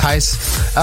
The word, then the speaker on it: Thijs: For the ones that don't know you, uh, Brooks, is real name Thijs, if I pronounce it right, yeah, you know, yeah. Thijs.